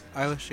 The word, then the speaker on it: Eilish: Is that how you say it? – Eilish, yeah.